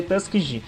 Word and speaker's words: Tuskegee: É Tuskegee que se escreve, Tuskegee mas se lê Tuskegee.